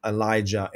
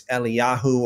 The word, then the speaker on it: Eliyahu: Typically, in the English speaking world, you call him Elijah and Elisha. But in the original, you know, it's Eliyahu or Eliyah versus Elisha.